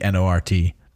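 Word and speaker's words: G'nort: Yeah, I believe it because a, it's apostrophe G, G apostrophe N O R T. Not in this so, issue, but eventually. Is it Gnort? I don't. I still pronounce it Nort. – It feels like it should be Nort and um, he is a Green Lantern, but he is incompetent and an idiot.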